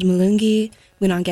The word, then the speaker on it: Mulungi Winange: It was called Mulungi Winange. Did I say that right? Perfect.